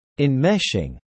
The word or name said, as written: enmeshing